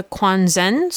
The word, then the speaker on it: Quanzhen: was a central practice of the Quanzhen school. I'm hoping I said that right. – Yep, that sounds until cool.